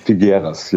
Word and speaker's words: Figueras: Figueras, ja.